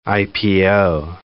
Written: IPO

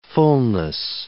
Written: fulness

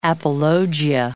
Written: apologia

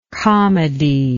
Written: comedial

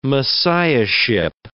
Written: messiahship

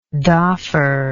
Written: doffer

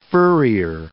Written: furrier

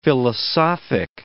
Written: philosophic

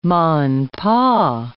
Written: ma-and-pa

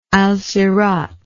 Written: alsirat

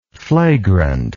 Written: flagrantly

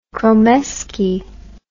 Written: kromeski

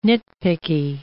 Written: nitpicky

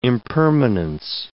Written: impermanence

